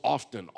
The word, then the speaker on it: often: And it's like O-F-F-T-T-E-N. I mean, it's like three syllables, often, often, often. And so I start laughing. I go, man, that guy's redneck. Listen to that. I wasn't raised in those parts. And she goes, what are you talking about? The way he says often. She said, that's the way you say it. I'm like, get out. She goes, no. It drives me nuts. The T is silent.